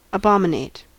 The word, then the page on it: abominate: American English